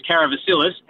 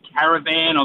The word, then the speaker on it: Karavasilis: Like, I've had people call me Caravan. I've had people that I've known for the last 20 years that still can't pronounce it properly. They call me Caravalis, Caravasilis, you name it, and I've been called it, mate.